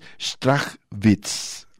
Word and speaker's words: Strachwitz: yo le decía Straswitz, pero al parecer es este em, alemán de ascendencia, entonces ahí voy, a intentar decirlo bien, Chris Strachwitz ahora sí lo dije bien, eh, este hombre es un refugiado no de la guerra mundial, sino de la posguerra.